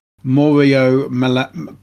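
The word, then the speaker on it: Malavasi: The majority of songwriting and production was carried out by Mauro Malavasia, that's how you pronounce it, and the bass player David Romani.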